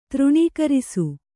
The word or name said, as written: ತೃಣೀಕರಿಸು